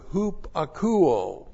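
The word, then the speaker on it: hupakuo (ὑπακούω): Secondly, there's the verb hupakuo. Hupakuo. That's H-U-P-A-K-O-U, long O.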